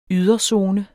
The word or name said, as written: yderzone